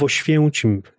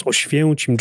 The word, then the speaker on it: Oświęcim: So I'm from the town of Oświęcim. This is exactly Auschwitz town. It's a complicated name to pronounce. Oświęcim.